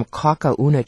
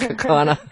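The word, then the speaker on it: Kaukauna: She wanted me to send her some Kakauna cheese. That's how she mispronounced Kakana.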